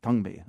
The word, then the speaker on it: Thangmi: And that's spelled T-H-A-N-G-M-I, Tungmi.